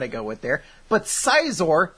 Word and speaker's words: Scizor: But upon looking up a pronunciation guide for Pokemon names, I recently discovered it's not Scizor, it's Scizor. – Yeah. – And that's nonsense. That sounds ridiculous. – If they wanted it to be pronounced Scizor, it would stay like S-C-Y, like Scyther is.